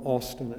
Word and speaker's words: Augustine: Some pronounce, it looks like it's Augustine or something like that, but they would call him Austin at times, A-U-S-T-I-N.